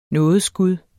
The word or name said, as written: nådeskud